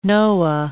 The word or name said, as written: Noah